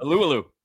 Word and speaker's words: Alualu: It was Olualu and Alualu. – Alualu. Alualu.